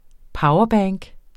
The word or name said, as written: powerbank